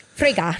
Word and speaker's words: Frigga: I don't know how to say her name. – Frigga. – Frigga. – You have to say it. Say it like Hannibal Lecter.